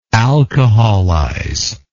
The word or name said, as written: alcoholization